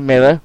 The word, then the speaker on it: Miller: People have known this for weeks, and the only reason that I didn't go down this road with Joe Miller, which rhymes with killer, is because it was everyone else was going with it, and I had faith that it wasn't going to be...